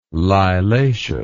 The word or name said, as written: lilaceous